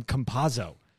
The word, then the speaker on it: Campazzo: They just keep calling him the Joker, but they have no problem saying Campazzo. It's just, it's a soft C on the back end, guys. It's not that hard.